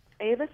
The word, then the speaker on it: Avis: First, Laura McBride, Avis, is that how you pronounce it? She's a woman whose marriage looks like it's falling apart. – Yes. Avis was a difficult character for me to write and a difficult character for me to finally understand.